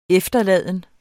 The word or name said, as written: efterladende